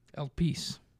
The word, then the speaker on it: Elpis: Elpis? – That's the one we're looking at, right? – Yep. – Yeah. – Elpis.